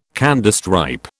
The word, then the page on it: Candystripe: British English